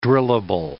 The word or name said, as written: drillable